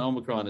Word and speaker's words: Omicron: Omicron is pronounced I'm a cold, by the way. Omicron, I'm a cold.